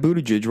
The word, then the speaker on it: Buttigieg: Pete Buttigieg, and I apologize for butchering all these names. – I think you actually got Buttigieg right, though.